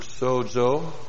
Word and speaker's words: sozo: The verb sozo. S long O Z long O means to save, means to keep, it means to preserve, to rescue.